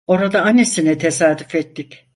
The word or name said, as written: ettik